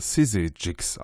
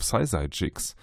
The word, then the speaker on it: SYZYGYX: Ähm, ähm, sie sagen selbst, es spricht sich äh, Sissy Jigs aus.